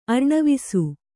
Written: ಅರ್ಣವಿಸು

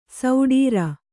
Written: ಷಡಾರ

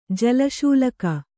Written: ಜಲಶೂಲಕ

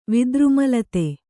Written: ವಿದ್ರುಮಲತೆ

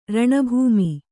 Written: ರಣಭೂಮಿ

